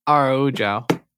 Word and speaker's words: Araújo: Araújo. Yeah, Viviane Araújo.